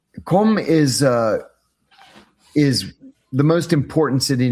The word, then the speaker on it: Qom: Launches near, I'm going to butcher this name, Qom, Q-O-M. – Qom, the holy city of Iran.